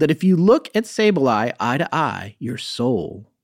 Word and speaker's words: Sableye: Yeah, Sableye.